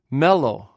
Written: mellw

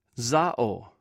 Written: zaw

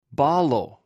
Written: ballw

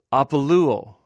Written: apoluw

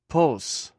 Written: pws

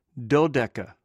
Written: dwdeka

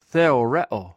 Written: qewrew

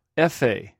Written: efh

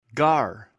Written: gar

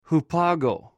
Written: hupagw

